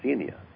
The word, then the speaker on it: Xenia: And Xenia is Greek for the word hospitality, pronounced Xenia. It's X-E-N-I-A, but pronounced Xenia like Xerox.